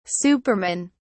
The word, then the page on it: supermen: British English